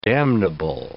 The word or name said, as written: damnable